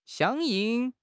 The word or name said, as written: 响应